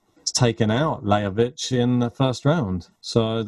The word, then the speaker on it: Lajovic: Thought an interesting one on the, the next one down was uh, Lajovic or Lajovic, which is probably the correct pronunciation, uh, against uh, Egor Gerasimov, which obviously yeah.